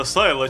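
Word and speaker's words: Silo: А я его Село читаю. – Село? – На заставке. – Наконец-то Антон Киф пожаловал в наши эфиры, потому что иногда проскальзывают такие английские словечки. – Точно, оно ж правда Сайло читается.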